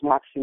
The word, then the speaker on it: Moxie: His name is spelled B-R-A. No, your name. How do you spell your name? – It's Moxie Mona. – Moxie. Okay. – M-O-X-I-E.